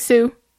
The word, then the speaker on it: Siu: C-U? Sue? S-I-U. It's from Eric Sue. Sue.